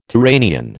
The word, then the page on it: Turanian: American English